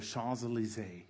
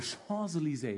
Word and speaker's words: Champs-Élysées: I mean, our first date was on the Champs-Élysées. Yeah, that's how you say it. Not Champs-Élysées. It's the Champs-Élysées. It just kind of rolls off your tongue.